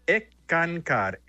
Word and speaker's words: Skankar: Se pronuncia ENCCAR. S-K-A-N-K-A-R.